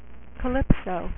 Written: Calypso

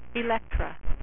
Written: Elektra

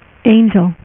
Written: angel

